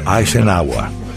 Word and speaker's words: Eisenhower: Se pronuncia Eisenhower. Eisenhower.